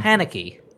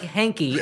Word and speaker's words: Haneke: Haneke. – That's close enough. – No, it is not at all. One sounds like Hanky, and one sounds like Haneke.